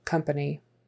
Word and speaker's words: company: Again, this O is pronounced like a short U. Company, company, company. And really, that second A is also pronounced like a short U when we're in um, conversation. Company.